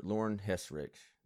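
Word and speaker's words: Lauren Hissrich: I'm very much looking forward to it because the main thing is the showrunner, Lauren Hesrick. I said that correctly, didn't I? – Yeah, I think so. – Okay. I think that's how she pronounced it in The Witcher Con.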